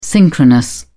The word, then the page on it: synchronous: British English